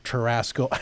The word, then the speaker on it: churrasco: But if you go to, I don't know, churrasco, you pronounced it better than churrasco I do.